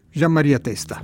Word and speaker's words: Gian Maria Testa: Vous avez choisi la Nave de Gian Maria Testa. C'est ça, j'ai bien prononcé, dites-le, mais ça sera mieux. Vous. – Si, Gian Maria Testa.